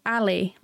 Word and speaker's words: ALIE: If you read it, it's spelled Ali, like A-L-I-E, guarded.